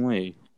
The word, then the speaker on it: Ang Lee: Ang Lee.